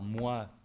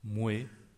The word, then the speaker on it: moi: Vous allez aussi, vous êtes susceptible d'entendre également en français québécois l'ancienne prononciation moué, toué. Attention, pas pour tous les mots qui s'écrivent avec oi, ça s'est surtout fossilisé avec les pronoms personnels moué, toué.